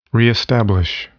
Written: reestablish

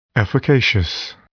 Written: efficacious

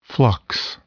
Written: flux

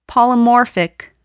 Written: polymorphic

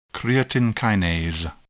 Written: creatine kinase

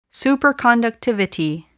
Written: superconductivity

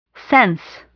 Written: sense

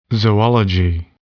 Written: zoology

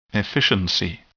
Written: efficiency

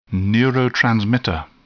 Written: neurotransmitter

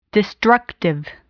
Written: destructive

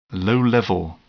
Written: low level

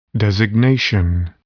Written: designation

